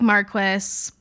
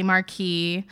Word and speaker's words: Marquis: It looks like Marquis, but it's really Marquis.